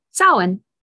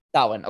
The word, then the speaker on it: Samhain: Samhain. – Samhain.